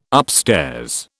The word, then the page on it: upstairs: British English